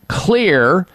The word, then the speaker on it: Xlear: Clear Rescue Nasal Spray, X-L-E-A-R. That's how Clear is spelled.